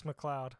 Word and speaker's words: McLeod: Yep, it's McLeod.